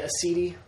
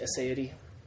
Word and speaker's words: aseity: They are things like aseity. – I was just going to say, how do you say that? – Other people say aseity, but aseity. I say aseity.